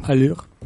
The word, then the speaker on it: Allure: Pues a mí por un 308 1.2 PureTech de 130 caballos con acabado Allure, ¿lo he pronunciado bien? Sí. Allure.